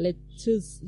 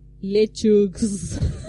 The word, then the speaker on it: lettuce: Y Rosy pronunció bien, lettuce. – Lettuce. – lettuce. Entonces Nuri se quedó así como, oh por Dios. Dice, ¿Por qué? ¿Cómo lo decís vos? Lechux.